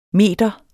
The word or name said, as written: meder